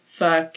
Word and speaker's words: pho: There was a restaurant called Pho King. And I was like, oh, well, I mean, that settles it. I mean, it has to be pronounced pho.